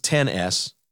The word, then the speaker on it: XS: iPhone XS, pronounced 10S. I said it the wrong way.